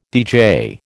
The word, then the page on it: DJ: American English